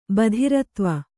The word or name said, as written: ಬಧಿರತ್ವ